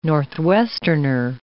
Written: Northwesterner

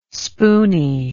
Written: spoony

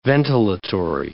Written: ventilatory

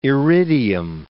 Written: iridium

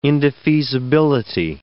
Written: indefeasibility

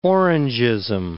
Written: Orangeism

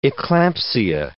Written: eclampsia

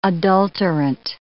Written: adulterant

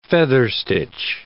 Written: featherstitch

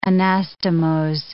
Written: anastomose

